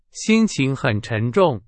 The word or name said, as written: 沉重